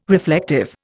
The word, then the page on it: reflective: Indian